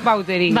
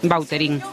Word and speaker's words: Vautrin: Vautrin. Pero aquí decimos Bauterín. – Claro, sí, sí. ¿No? Pues, es que si me pregunta exactamente cómo se pronuncia, pues así. Claro, pero sí. Yo ya soy Bauterín. – Ya. – Está españolizado total.